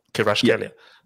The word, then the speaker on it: Kvaratskhelia: I think it's Kvaratskhelia. Kvaratskhelia. Yeah, Kvicha Kvaratskhelia.